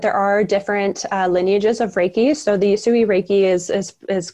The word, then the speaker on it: Reiki: So it's Reiki. – Yes. – Correction to me. – You're saying it just fine.